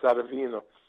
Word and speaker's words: Serafino: My grandfather, who I was named after, his name in Italian is Serafino, and in Italian it's pronounced Serafino.